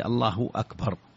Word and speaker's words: الله أكبر: لا شك أن الصيغة هي الله أكبر، الله الهاء مضمومة لفظ الجلالة، وأكبر الهمزة مقطوعة، همزة قطع، همزة قطع، الله أكبر، هذا هو الأصل في الصياغة.